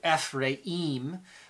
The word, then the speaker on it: Ephraim: Uh, Ephraim is how you would pronounce it in the Hebrew. Ephraim. Um, most people, uh, English, call it Ephraim, call him Ephraim